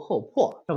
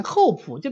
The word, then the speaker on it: 厚朴: 当然这里呢，呃，我们要学中药的话，应该读厚朴是吧？啊，但是这是个多音字，所以你把它改成另外一个音，厚朴就变成那种对德行的要求。